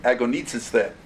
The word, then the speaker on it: agonizeste: The Greek word is agonizeste. That's the best word to translate. That's the best way to pronounce it. Agonizeste. Do you hear the English word that we get? Agonize.